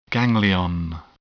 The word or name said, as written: ganglion